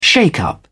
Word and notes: shake-up: British English

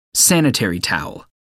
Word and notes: sanitary towel: American English

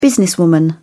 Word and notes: businesswoman: British English

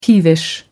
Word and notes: peevish: American English

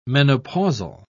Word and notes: menopausal: British English

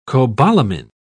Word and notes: cobalamin: British English

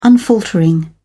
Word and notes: unfaltering: British English

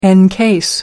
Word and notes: encase: American English

